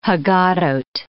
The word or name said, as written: Haggadoth